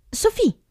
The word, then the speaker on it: Sophie: Sofì, piacere. – Come, scusa? – Sofì. Si scrive S-O-P-H-I-E.